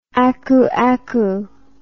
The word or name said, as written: akuaku